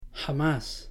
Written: jamás

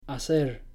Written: hacer

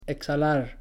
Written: exhalar

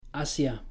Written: hacia

